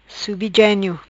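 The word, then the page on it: Genio Subi: patua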